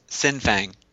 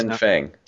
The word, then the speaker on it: Sin Fang: Sing, is that his name? Sing Fang. Am I saying that right? Yeah. – Sin Fang. There's Sin Fang. – no G in the first.